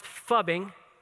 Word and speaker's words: phubbing: Maybe you've heard of it, maybe you haven't, but it's spelled, I'll spell it first, P-H-U-B-B-I-N-G. It's pronounced fubbing, and it's a combination of two words, phone and snubbing.